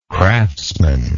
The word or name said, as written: craftsmanship